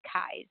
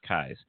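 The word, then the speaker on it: Keyes: It's K-E-Y-E-S, but it's pronounced Kies. – Yes, it looks like Keys, except there's an extra E, but it's Kies.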